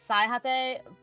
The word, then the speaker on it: Saihate: It's Saihate by Setarin. Yeah, you got it right.